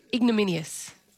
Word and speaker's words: ignominious: Ignominious. Is that right? – No. – Not igloo. – How do you say it? – Ignominious. – Ignominious. – There's no L. – Minious. Yeah, remove the L.